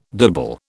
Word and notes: dibble: British English

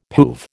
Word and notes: poove: American English

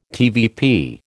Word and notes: TVP: American English

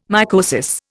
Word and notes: mycosis: Indian

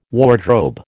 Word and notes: wardrobe: American English

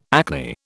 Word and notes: acne: British English

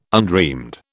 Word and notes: undreamed: British English

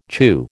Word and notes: chew: American English

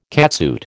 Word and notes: catsuit: American English

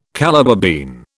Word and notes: Calabar bean: British English

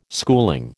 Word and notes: schooling: American English

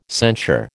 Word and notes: censure: American English

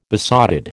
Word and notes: besotted: American English

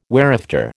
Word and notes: whereafter: American English

